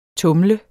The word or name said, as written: tumle